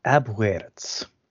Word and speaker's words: Abwärts: Or by the German name, Abwärts. I'm sure I'm butchering the pronunciation, but... – I took German in university. It's Abwärts. The umlaut over the A, it gives it an E sound. So Abwärts.